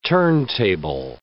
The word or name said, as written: turntable